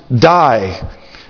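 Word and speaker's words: die (δεῖ): It's a little Greek word that is pronounced die.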